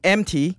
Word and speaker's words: EMT: Is that how it's actually pronounced? – I mean, MT. – Okay, that's how I say it, is MT.